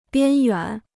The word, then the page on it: 边远: biān yuǎn